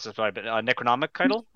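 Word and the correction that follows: Necronomidol: right? – Necronomidal.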